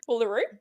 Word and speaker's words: Uluru: It's like the outback, the oh, middle Uluru. of nowhere. Uluru.